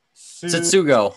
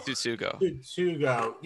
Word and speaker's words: Tsutsugo: Yoshitomo Tsutsugo. – Tsutsugo.